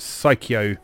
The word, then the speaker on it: Psikyo: Fans of shoot 'em ups, there are two, how do you pronounce it? Psycho. – Yeah, sounds right.